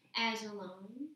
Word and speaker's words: Ajalon: J-A-M-I-K-A, Jamika Ajalon, A-J-A-L-O-N.